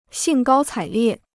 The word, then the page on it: 兴高采烈: xìng gāo cǎi liè